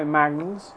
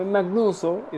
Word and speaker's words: Magnuz: That's my Edwin Hernandez, but you can call me Magnus, M-A-G-N-U-Z. It's also M-A-G-N-U-Z-0, but the 0 is silent, but it's there. The number 0 is there after Magnus with a Z, but it's silent. So you just say Magnus. So don't call me Magnuso, it's Magnus.